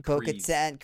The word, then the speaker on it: Bo-Katan: Bo-Katan Kreese, and you know, the upcoming characters.